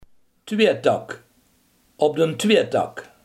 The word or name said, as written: wegsplitsing